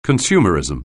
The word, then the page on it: consumerism: anglais